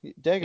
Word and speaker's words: Dagobah: Oh yeah, Yoda and all Dagobah. Yeah. – Dagobah. I'm surprised you pronounced it right.